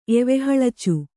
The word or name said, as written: ಎವೆಹಳಚು